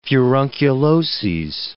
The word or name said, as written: furunculoses